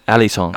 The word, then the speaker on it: Alisson: Alisson.